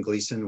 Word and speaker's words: Gleason: My name is Kevin Gleason, which is spelled G-L-E-A-S-O-N.